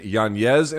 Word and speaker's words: Yanez: My Adrian Yanez interview, because that's apparently how you pronounce his name now, he told me, is on the site as well.